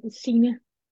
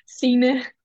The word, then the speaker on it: Signe: how is it actually pronounced? Because I know I don't pronounce it correct. – Like my first name? – Yes, your first name. – Sina. – Okay, say one. – Sina. – Sina. So yeah. I said it right, but just not in the right amount of time, right? Sina, but Sina. No. – I mean, you're pretty close. Pretty close.